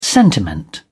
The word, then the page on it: sentiment: British English